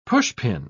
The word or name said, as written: pushpin